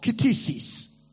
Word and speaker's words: ktisis: That word creature is the Greek word ktisis.